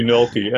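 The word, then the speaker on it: Nölke: So you can either spell that with an O or an O with 2 points, this kind of metal way of putting 2 points over the O, or you can have an OE. So probably you pronounced it Nwelki. No, how did you pronounce it? Well, I'm not sure, but I mean, in German, it would be Nwelki, and it's not really important anyway.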